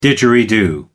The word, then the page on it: didgeridoo: American English